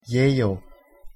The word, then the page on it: 예요: Korean